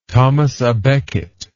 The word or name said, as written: thomas a becket